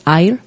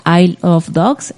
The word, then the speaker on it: Isle: Eh, isla en inglés es Isle, entonces si pronuncian I of Dogs, es como I love dogs.